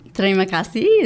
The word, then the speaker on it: Terima kasih: Terima kasih is thank you. – Oh, I know. Terima kasih.